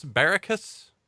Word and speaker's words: Baracus: Sergeant Bosco B.A., was it Barracus? – Baracus. – Baracus. See, it was the third option.